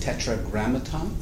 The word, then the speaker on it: Tetragrammaton: And it means the hidden name of God, Tetragrammaton. Did I say it right? – That's it, perfect. – So here I was, a very heady 17-year-old, going, Tetragrammaton.